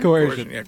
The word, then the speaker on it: coercion: How do you say it? – Coercion? – Coercion? – Coercion. – Coercion. – So, I knew what you were saying. – Yeah, thank you. – So coercion.